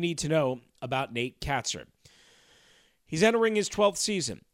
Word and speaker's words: Kaczor: First of all, his last name is spelled K-A-C-Z-O-R, but it's pronounced Katzer. Katzer. Occasionally, I'm going, just be honest, as I've already done, call him Katzer. It's Katzer. Katzer. K-A-T-S-I-R is how you would basically pronounce it phonetically, or K-A-T hyphen S-I-R.